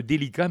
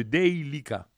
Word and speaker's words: Deylika: C'est pas le délicat, mais le Deylika.